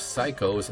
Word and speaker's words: Saicos: Those Psychos, and that's spelled S-A-I-C-O-S.